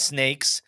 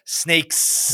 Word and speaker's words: Snakesss: First one is Snakes. This is a weird trivia game. – Hold on. I don't think you said it right. – Oh, sorry. Snakes. – There we go.